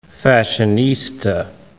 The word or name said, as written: fashionista